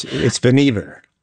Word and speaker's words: Vannevar: You can tell us how to pronounce it here. – It's Vannevar. – It's Vannevar.